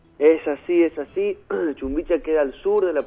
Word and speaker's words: Chumbicha: Así es, tuviste la posibilidad de estar, ahora sí lo voy a pronunciar bien, lo prometo, es Chumbicha,